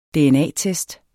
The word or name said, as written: dna-test